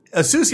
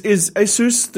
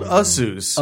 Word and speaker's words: Asus: Is Asus the correct pronunciation? – Well, it's interesting because we've been told Asus. – Asus, because I always say Asus. – No, I guess that's the wrong one. That's wrong for sure.